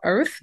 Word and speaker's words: Irth: So as you mentioned, our app is called Earth, which is like the word birth, but we drop the B for bias. So it's spelled I-R-T-H.